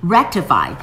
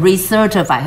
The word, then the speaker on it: rectified: 但是如果说像淘宝台湾，他们要它纠正，要它改正，to be rectified, to be rectified，你就不要念成 recertified，很像哦，不像哦，不是哦，这是 re 这个呢，to be rectified，rec 发 k 的音，rectify 就是纠正的意思。